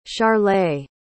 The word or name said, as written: charleigh